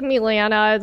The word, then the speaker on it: Milena: It was like Milena. It's just the Serbian version, okay. But Milena, that's what you got here.